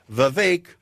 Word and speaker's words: Vivek: Just remember one thing. Vivek rhymes with cake.